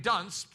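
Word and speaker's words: Duns: And if you pronounce his surname Dunce properly, you would find that it sounds just like our word dunce.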